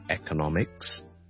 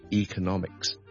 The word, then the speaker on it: economics: 听一次，第一个是 electronics，第二个是 electronics，亦都得，有好多字都系类似咁样，譬如经济学啦，我哋 economics， or we can say economics，亦都得，两个都系广为接受发音嚟。